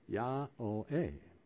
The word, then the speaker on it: Yahweh: The Hebrew wow has the marks of a long O over it, so it should be pronounced as O or A-O. Therefore, the name is pronounced Ya-O-A.